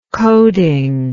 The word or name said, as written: coding